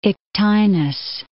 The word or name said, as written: Ictinus